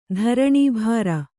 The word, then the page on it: ಧರಣೀಭಾರ: Kannada